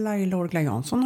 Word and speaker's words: Laila Orgla Jansson: Några har faktiskt kommit fram till mig och frågat Hur uttalar du ditt namn? Mm, Laila Orgla Jansson har jag sagt. Men du säger någonting annat på podden. Ja, men jag säger ju Laila O. Jay, jag är ju lite internationell sådär.